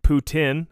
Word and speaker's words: poutine: Poutine, actually. That's how it's said.